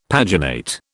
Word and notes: paginate: British English

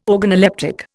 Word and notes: organoleptic: Indian

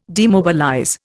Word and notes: demobilize: Indian

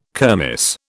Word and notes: kermis: British English

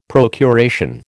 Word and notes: procuration: American English

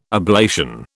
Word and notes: ablation: British English

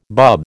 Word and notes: bub: American English

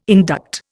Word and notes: induct: Indian

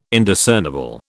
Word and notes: Indiscernible: British English

